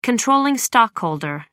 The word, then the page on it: controlling: American English